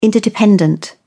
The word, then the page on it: interdependent: British English